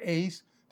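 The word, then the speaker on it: eis (εἰς): It is in Greek, E-I-S, the preposition eis or ace, depending on your Greek pronunciation, and it means directional, going from outside into.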